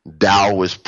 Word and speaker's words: Taoist: I study martial arts and that's, it's a whole Taoist, it's a Taoist, even though it's a T, it's pronounced with a D, Taoist program of meditations and Qigongs and et cetera that deals strictly with just this topic alone.